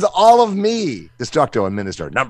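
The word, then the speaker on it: MxNxSxTxR: So Minister, not Monster. Minister.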